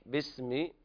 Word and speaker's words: باسم: فسبح باسم ربك العظيم، وهنا نبين البحة في الحاء، فسبح باسم، هنا الألف همزة وصل طبعا لا تنطق حال الوصل، باسم ربك العظيم، وكتبت هكذا في المصاحف.